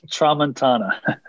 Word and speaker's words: Tramontana: Tramontana.